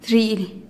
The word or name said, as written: zhi'ilih